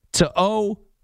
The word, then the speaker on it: To'o: Henry To'o, which you can hear the difference. Like, Coyote, Coyote. To-o, to-o.